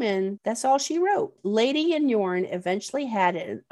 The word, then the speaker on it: Leidi: His new wife, it's spelled L-E-I-D-I, but you pronunciate it lady.